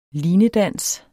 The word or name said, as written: linedans